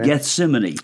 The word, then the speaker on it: Gethsemane: Gethsemane.